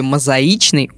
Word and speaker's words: мозаичный: Хоть и мозаика, но в слове «мозаичный» ударное «и».